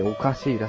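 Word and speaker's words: 可笑しい: えーこれ、これ一番上なんて読むんですかあ、これおかしいって読むんですかえー、可能の可に笑いって書いておかしいらしいですね。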